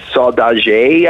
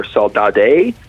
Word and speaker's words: Saudade: It's, I believe, okay, this is a problem because it's a Portuguese word. It's Saudade, I believe, or Saudade.